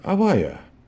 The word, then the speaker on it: Авая: Произносим его как Авая или Юд-Кей-Вав-Кей. Используем это имя так, чтобы не произносить его имя напрасно, поэтому говорим Авая.